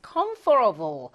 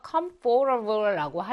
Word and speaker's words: comfortable: comfortable, comfortable인데 t 발음이 약화되어서 comfortable라고 하게 될 수도 있어요.